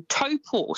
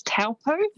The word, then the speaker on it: Taupō: but famous spots including Taupō. So quite often you'll hear that said as Taupō.